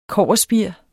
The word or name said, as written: kobberspir